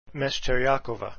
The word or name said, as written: mescheriakova